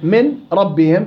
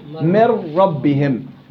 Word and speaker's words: من ربهم: وأما النون في الراء في نحو من ربهم تقلب النون راء. إذن من ربهم تصبح من ربهم من ربهم. ثم تضغم الراء في الراء وعندها يصير النطق براء مفتوحة مشددة بعد اللام بعد الميم من ربهم من ربهم.